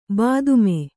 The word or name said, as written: ಬಾತ್ಮಿ